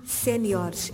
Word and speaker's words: séniores: A tónica, portanto, são palavras que graficamente no plural não têm qualquer acento, mas na pronúncia são articuladas como graves, juniores e séniores. É esta a pronúncia correta do plural dessas palavras.